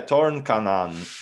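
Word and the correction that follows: Torņakalns: Oh my God, I can't even pronounce it. Tornakalns. Tornakalns is this part of Riga.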